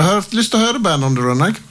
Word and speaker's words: Band on the Run: Jeg må ta meg headsetet for å nå tak i Band on the Run. Det skal uttales sånn.